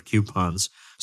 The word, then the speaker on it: coupons: And by the way, yes, that is the correct pronunciation of coupons. So do not correct me. That is the correct one.